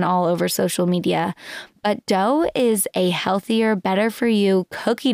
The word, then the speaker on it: Deux: Sabeena, Ladha is the CEO and founder of Dough. It's spelled D-E-U-X.